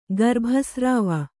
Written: ಗರ್ಭಸ್ರಾವ

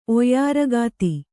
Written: ಒಯ್ಯಾರಗಾತಿ

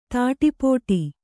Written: ತಾಟಿಪೋಟಿ

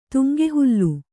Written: ತುಂಗೆಹುಲ್ಲು

